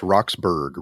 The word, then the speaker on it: Roxburgh: I just want to get his name right. It's Roxburgh. – Roxburgh? Oh, I thought it was Roxburgh.